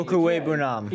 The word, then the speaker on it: Okwuegbunam: Okwuegbunam.